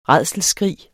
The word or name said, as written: rædselsskrig